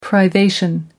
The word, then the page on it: privation: American English